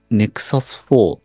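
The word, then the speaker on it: Nexus 4: 次で、まあ真ん中に戻って、その後は、今日は、ネクサス4 って読むんですかね、これは。 – あ、はい、はい。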